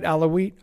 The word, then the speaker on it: Alawite: The United States stands with Syria's religious and ethnic minorities, including its Christian, Druze, Alawite. Is that, did I say that right? Alawite? – Yeah.